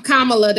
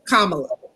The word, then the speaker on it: Kamala: And so for me, this election, I mean, with Sister Kamala, is that, how do you say her name? – Kamala, that's an HU graduate like myself. So, you know, I'm absolutely proud of Bison over here. So yes, Kamala.